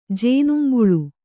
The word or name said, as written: ಜೇನುಂಬುಳು